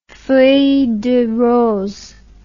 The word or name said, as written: feuille de rose